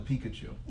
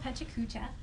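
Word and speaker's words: PechaKucha: We called it the Pikachu. – Yes, but it's actually called Pechacucha, and it's a way of doing presentations within a set amount of time.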